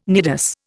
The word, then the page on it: nidus: Indian